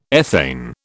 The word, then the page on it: ethane: British English